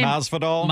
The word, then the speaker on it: Masvidal: Masvidal.